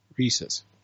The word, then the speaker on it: Reese's: Reese's. – not Reese's. – No, it's not Reese's Pieces. – It's not Reese's. It's Reese's.